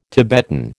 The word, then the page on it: Tibetan: American English